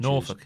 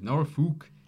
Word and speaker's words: Norfolk: you get the pronunciation correct? He's like, yeah, because it's Norfolk. And he's like, yeah, that's how you pronounce it.